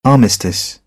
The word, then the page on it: armistice: British English